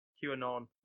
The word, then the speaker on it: QAnon: QAnon. It's like Anon as in anonymous.